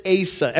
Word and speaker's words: Asa: Many people are like, how do you pronounce it? Asa. Everyone say that with me.